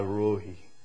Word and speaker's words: Rohi: This is Yahweh Rohi, R-O-H-I in the Hebrew. It's usually pronounced with the H silent, Rohi.